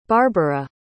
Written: barbara

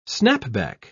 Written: snapback

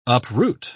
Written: uproot

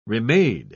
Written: remade

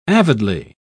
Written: avidly

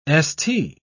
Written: st

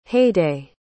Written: haidée

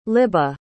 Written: libba

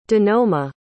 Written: donoma

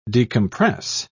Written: decompress

